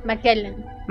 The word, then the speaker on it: McKellen: Ian McKellen. ¿Lo dije bien? Sí, McKellen.